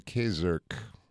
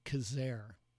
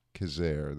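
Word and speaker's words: Quezergue: Well, he pronounced it Kazer. – Kazer, there we go.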